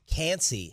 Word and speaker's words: Kancey: And how do you say the last name? – Kalijah Kancey. – Kancey. – K-A-N-C-E-Y.